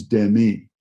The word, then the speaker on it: Demi: And the guy looks at me and he goes, Demi Moore. She had told everyone that her name, the pronunciation of her name truly in the French is Demi.